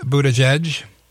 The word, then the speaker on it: Buttigieg: We talked last week about Pete Buttigieg.